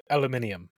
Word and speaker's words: aluminium: Aluminium. – I knew they pronounced it aluminium, and I was like, why are they pronouncing it that way? There's no I there.